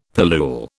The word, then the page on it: pillule: British English